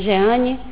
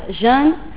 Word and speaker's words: Jeanne: Seria Jeanne, seria em francês, porque eu sou uma brasileira que tem um nome francês, mas a pronúncia em português no Brasil falamos Jeanne, não? Com J e dois N's, hein? Porque é francês.